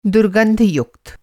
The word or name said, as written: दुर्गंधयुक्त